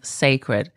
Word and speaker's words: Cécred: Next, let's look at some of the conversations around Beyonce's new haircare brand, Cécred. So it's spelled C-E-C-R-E-D. The C-E is, you say it as if it's Beyonce. So that's where the Cécred comes in.